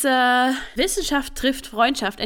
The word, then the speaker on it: Wissenschaft trifft Freundschaft: It's Wissenschaft trifft Freundschaft, and you pronounced it completely correct last episode.